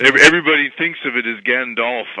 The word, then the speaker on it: Gandalf: The question was, how do you pronounce G-A-N-D-A-L-F? And, in his very heavy Oxfordian come South African accent, he said, well, it's Gandalf. And I said, okay, can you tell me more? And he says, well, it rhymes with half, like half a sixpence.